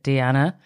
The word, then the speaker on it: Diana: I think she pronounces it Deanna, from The Real Housewives of Beverly Hills.